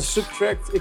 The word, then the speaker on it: SBTRKT: Zijn naam schrijf je als S-B-T-R-K-T. Maar spreek je uit de Subtract.